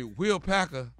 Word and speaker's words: Will Packer: So you telling me Will Packer, Will? – You said it right the first time.